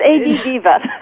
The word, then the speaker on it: ADDiva: Did I get it correct this time? – Well, it was close. It's ADDiva.